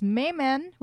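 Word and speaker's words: Maiman: In 1960, Ted, I believe his last name is pronounced Mayman, was the first man to shoot a laser.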